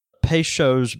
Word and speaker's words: Peychaud: From Jack, he says, heard y'all struggling with how to pronounce Peychaud's bitters. Having grown up watching the Justin Wilson cooking show on Louisiana Public Broadcasting TV, I can tell you with full confidence that it's pronounced Peychaud. As Justin Peychaud would say, I guarantee.